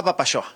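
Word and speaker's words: Apapaxoa: Abierta a Papashoa, se escribe con X, Juan, ¿eh? Claro. A Papashoa, se pronuncia como si fueras de Chihuahua, pero es a Papashoa.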